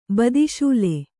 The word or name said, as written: ಬದಿಶೂಲೆ